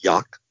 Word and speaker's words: Jauch: When I was a kid, I was forced to take my stepfather's last name, and it was spelled J-A-U-C-H, pronounced Yauch.